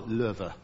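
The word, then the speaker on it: Loewe: Carl Lerver. Yes, pronounced Lerver, but spelt the same as Frederick Lowe, who wrote Call the Wind Mariah.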